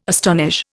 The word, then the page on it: astonish: Indian